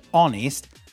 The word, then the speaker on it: honest: The word honest has a H in the spelling, but in the phonetics, in the pronunciation, it doesn't.